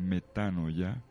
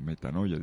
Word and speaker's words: metanoia: En griego, la palabra griega metanoia, o metanoia dicen algunos, que está mal pronunciado, metanoia, significa cambio de mentalidad, que es el punto de partida del cambio de actuar.